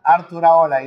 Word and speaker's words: Arturaola: Arturaola is Basque, very Basque.